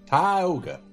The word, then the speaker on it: Tioga: I grew up in a small town in Louisiana right outside of Alexandria called Tioga. Tioga. That's how you pronounce it.